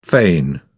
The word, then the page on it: fain: British English